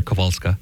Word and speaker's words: Kowalska: And if you're looking at it spelled in English, yes, it says Kowalska. I'm giving it at least close to the proper Polish pronunciation here, Kowalska.